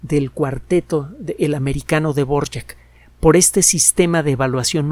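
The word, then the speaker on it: Dvořák: Borjak. Se escribe de Borak, D-V-O-R-A-K, Antonin Borjak.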